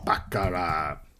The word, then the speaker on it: baccarat: Oh, play baccarat. Have to type the T even if you don't pronounce it.